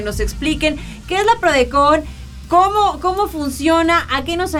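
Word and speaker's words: PRODECON: Entonces, justamente existe la PRODECOM. ¿Lo dije bien? – ¿PRODECOM? – PRODECOM con N.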